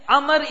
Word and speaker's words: Amr: But in Arabic, Amr is written with a waw at the end. Although it's not pronounced, to differentiate between Umar.